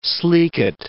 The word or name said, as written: sleekit